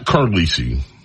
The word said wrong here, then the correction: Carlisle: The Countess of Conspiracy sent me a note. Excuse me. Her name is pronounced Carlisle.